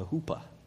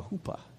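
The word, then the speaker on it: chuppah: This is called, it's spelled like chupa, but it's actually called a hoopa. So not a chalupa, right? It's not a beefy burrito thing. It's actually a hoopa.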